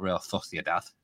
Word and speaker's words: Real Sociedad: But, like I said, I have no complaints about Real Sociedad. is how you pronounce it, whenever I've seen them in Champions League.